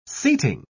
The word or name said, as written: seating